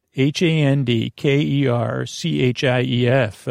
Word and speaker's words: handkerchief: Uh, h a n d k e r c h i e f uh, handkerchief. Uh, silent D handkerchief, uh, handkerchief.